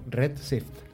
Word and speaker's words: Redshift: Sí, sí, además lo has pronunciado bien, que ya es un hito. – ¿Cómo lo he dicho? – Redshift.